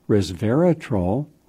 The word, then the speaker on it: Resveratrol: Resveratrol, R-E-S, Vera, V-E-R-A, Troll, three syllables, T-R-O-L.